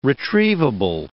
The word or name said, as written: retrievable